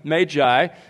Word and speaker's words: Magi: Now, let me just at the beginning say to you that I'm going to pronounce it that way throughout this sermon, Magi, because, as I learned a few years ago, that is the only pronunciation in the dictionary.